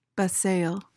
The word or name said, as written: besaiel